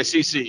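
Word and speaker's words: Cec: It's short for Cecilia, so it's, I believe it would be pronounced CC.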